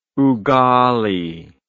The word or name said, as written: ugali